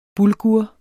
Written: bulgur

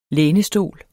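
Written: LÆNESTOLE

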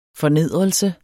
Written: fornedrelse

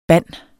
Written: band

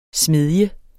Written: SMEDJEN

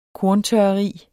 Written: korntørreri

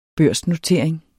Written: børsnotering

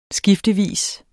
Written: skiftevis

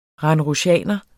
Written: randrusianer

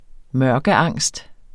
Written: mørkeangst

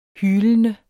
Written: hylende

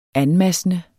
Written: anmassende

